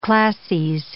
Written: classes

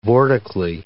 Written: vortically

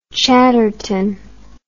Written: chadderton

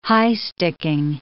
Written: high-sticking

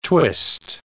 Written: twist

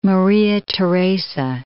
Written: Maria Theresa

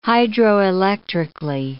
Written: hydroelectrically

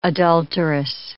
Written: adulteress

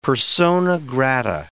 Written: persona grata

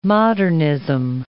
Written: modernism

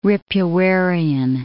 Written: Ripuarian

